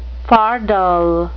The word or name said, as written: fardel